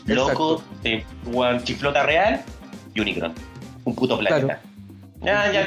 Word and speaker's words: Unicron: Oye, hay que decir que yo creo que aquí voy a unir fuerzas entre todos, que también una mención mega honrosa, Omicron, po weón. – Omicron. Unicron. Oh, Unicron, puta. siempre, eh, también, siempre, siempre lo digo mal, weón.